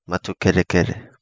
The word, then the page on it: Matukerekere: Kapingamarangi